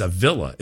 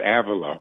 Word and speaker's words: Avila: Somebody said Avila. It's a villa, isn't it? Or how do you say it? – St. Teresa of Avila.